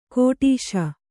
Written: ಕೆಟ್ಟಾಶೆ

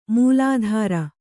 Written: ಮೊಲೆತೊರೆ

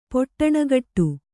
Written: ಪೊಟ್ಟಣಗಟ್ಟು

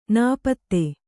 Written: ನಾಪಿತ

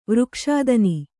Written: ವೃಕ್ಷಾದನಿ

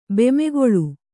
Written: ಬೆಮೆಗೊಳು

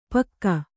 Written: ಪಕ್ಕಾ

